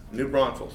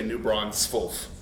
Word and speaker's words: New Braunfels: New Braunfels. – Thank you for saying that correctly. I hate it when people say New Braunfels.